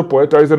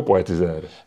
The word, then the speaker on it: Poetizer: A čte se to Poetizer nebo Poetizer? – Obojí je možný.